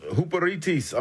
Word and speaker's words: huperetes: Servants is the Greek word, and it's pronounced huperites. I'm going to spell it for you because I want you to get this. Huperites. H-U-P-E-R-E-T-E-S. I say again, H-U-P-E-R-E-T-E-S.